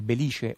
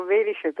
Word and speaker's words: Belice: tutti dicevano Velice perché venendo dal nord eh, ovviamente eh, mm, poco conoscendo il siciliano non, eh, non potevano apprezzare il belice come si dice invece in siciliano.